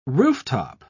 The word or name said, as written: rooftop